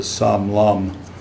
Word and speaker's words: Long: But then his name was Sam Long, spelled just like Sam Long, but you pronounce it Sam Lum. That's the way the Vietnamese pronounce it.